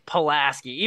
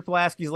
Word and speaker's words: Pulaski: And I said, how do you say Kashmir Pulaski's last name? And she would say Pulaski. So how is the street Pulaski? She's like, well, the street's Pulaski. I said, no, it's not Pulaski. It's not. So yeah, Pulaski. That's how you say it.